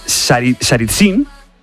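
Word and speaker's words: Tsaritsyn: A ver si lo digo bien, ¿eh? Saritsyn... – Bien.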